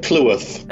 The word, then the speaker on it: Clewarth: How do you pronounce your last name? – Oh, so it is uh, Clewarth. – Ah, I was doing it right.